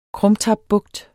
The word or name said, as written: krumtapbugt